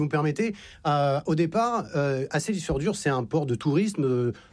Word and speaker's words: Seyðisfjörður: Seyðisfjörður.